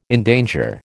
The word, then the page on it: endanger: American English